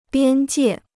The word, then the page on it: 边界: biān jiè